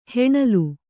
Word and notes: ಹಣ್ಣೆಲೆ: haṇṇele; noun

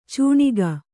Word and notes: ಚೂಣಿಗ: cūṇiga

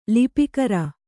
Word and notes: ಲಿಪಿಕರ: Kannada

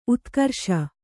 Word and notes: ಉದ್ಗಾರಿಸು: udgārisu

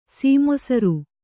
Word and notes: ಸೀಮೊಸರು: sī mosaru

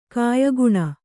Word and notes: ಕಾಯಗುಣ: Kannada

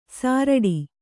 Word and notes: ಸಾರಡಿ: sāraḍi

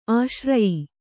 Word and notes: ಆಶ್ರಯ: āśraya; kannada

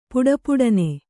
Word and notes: ಪುಟಪುಟನೆ: puṭapuṭane

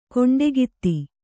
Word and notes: ಕಂಠೋಕ್ತ: Kannada

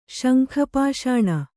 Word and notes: ಶಂಖಪಾಷಾಣ: śankha pāṣāṇa

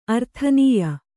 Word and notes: ಆರಾಧನೀಯ: ārādhanīya

